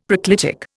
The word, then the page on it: proclitic: Indian